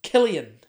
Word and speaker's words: Cillian: I thought it was Cillian Murphy, C-I-L-L-I-A-N, but it turns out the correct pronunciation in the world of Ireland no is owls? – Killian. – Oh, Killian. Yeah. Ah.